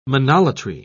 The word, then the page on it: monolatry: British English